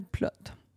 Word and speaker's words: Plote: Ça s'écrit P-L-O-T-E. On ne le prononce pas comme ça en anglais, mais moi, je l'appelle Plott.